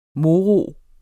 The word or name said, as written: moro